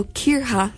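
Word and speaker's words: coirce: So when I was listening there, I also learned that it is Kirha instead of course for oats. So Kirha is the pronunciation.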